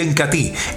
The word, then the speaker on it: Incatea: Entonces el Incati, se escribe Incatea, pero se pronuncia Incati,